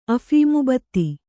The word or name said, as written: ಅಫೀಮುಬತ್ತಿ